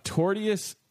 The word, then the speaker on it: tortious: When a woman ordered lasagna at a restaurant in the suburbs, she didn't expect her meal to come with a side of tortuous conduct. Am I saying it wrong? – Torturous? – There's no other R. T-O-R-T-I-O-U-S. – Tortious conduct? – Tortious?